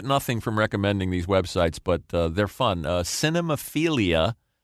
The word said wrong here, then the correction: Cinephilia: Cinephilia. I added a syllable like Homer there.